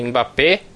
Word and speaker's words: Mbappé: O Matheus me corrija se está errado a pronúncia dele, mas esse jogador é o, o Mbappé, tá certo? Aham. Uhum.